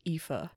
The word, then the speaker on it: Aoife: I think it's pronounced Aoife. – That's what Google told me. So we're going to go with Aoife. It's like a Siobhan, which is very popular.